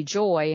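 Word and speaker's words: Joye: And he was told my name was to be Joy. And if you notice, it is spelled with an E, J-O-Y-E.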